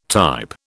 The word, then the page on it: type: British English